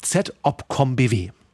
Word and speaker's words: ZOPCOM-BW: Und für die Aussprache dieser Abkürzung habe ich extra im Bundesverteidigungsministerium nachgefragt. ZOPCOM-BW.